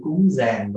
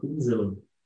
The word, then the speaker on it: cúng dường: Ví dụ như là giữa chữ cúng giàng và cúng dường, người miền Nam thì thường đọc là cúng dường, miền Bắc hay đọc là cúng giàng, hay là tỷ tỳ kheo, có khi có bản để là tỷ khiêu.